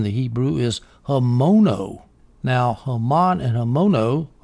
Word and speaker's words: Haman: The Hebrew for Haman, and of course Haman is how I'm pronouncing it in English. The Hebrew is Haman.